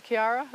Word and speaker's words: Chiara: And also, as I'm listening to you speak about Blessed Chiara, is that, did I say it right? – Exactly.